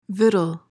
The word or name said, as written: victual